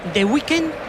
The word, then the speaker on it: The Weeknd: Yo creo que es The Weeknd.